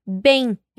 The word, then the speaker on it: bem: Ahora, intentemos una vez más la frase de la chica y atención a la pronunciación de bien, que decimos bem en portugués.